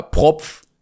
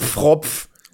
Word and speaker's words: Pfropf: Also warum sagst Pfropf, du nicht ja, Pfropf oder Propf? Nein, es ist Pfropf. Oder Pfropf? Ja. Dann hast du mir das P nicht drin.